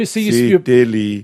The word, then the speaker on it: Sitili: Sitili. See? – Sitili. – No, Sitili. – Sitili. – Tuponiua. – See, your pronunciation's off. That's why I can't spell them. – Sitili Tuponiua. – Sitili. S-I-T-I. – Yes. – L-I. – Yes. – T-U-P-O-U. – Yes. Niua. – N-I-U-A. – Yes.